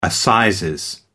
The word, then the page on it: assizes: American English